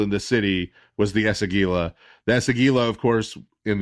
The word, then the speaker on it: Esagila: The first and most important of which is the renovation of the Esagila, which I pronounced correctly this time. So the renovation of the Esagila.